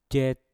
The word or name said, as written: jet